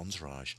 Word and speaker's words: Entourage: And so what I did do, don't laugh, but I tried Microsoft Entourage. Is that how you pronounce it? – I believe so.